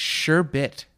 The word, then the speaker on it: sherbet: Sherbert. – It's sherbet.